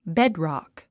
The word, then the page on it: bedrock: béd・ròck; ベッドロック